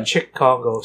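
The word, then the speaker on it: Cheick Kongo: Yeah, Chick Congo. C-H-E-I-C-K space K-O-N-G-O.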